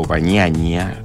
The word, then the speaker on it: Bagnaia: Pecco Bagnaia, se pronuncia así en italiano,